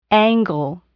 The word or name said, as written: angle